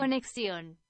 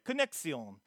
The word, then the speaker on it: Conexión: It's Conexión. That is the single that they put out. Translates into connection. Let's get a little better pronunciation. – Conexión. – Okay, I did all right. Conexión.